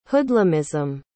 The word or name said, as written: hoodlumism